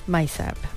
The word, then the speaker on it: MICEB: And according to the Tourism Ministry's agency, Malaysia Convention and Exhibition Bureau, how do you say this, MICEB? – MICEB. MICEB is better.